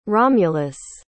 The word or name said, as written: romulus